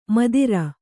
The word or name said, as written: ಮದಿರೆ